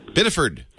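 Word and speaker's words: Biddeford: Bitterford, how do you say that? Bitterord, Maine. – Bitterford. – Oh, we left out a f. Bitterford. – Yeah, that's basically it.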